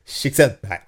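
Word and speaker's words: Csíkszentmihályi: Ja, men det uttalas tydligen Csikszentmihalyi.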